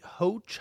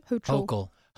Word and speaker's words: Hochul: So, Governor uh, Kathy Hoch, how do you pronounce it? Hochul.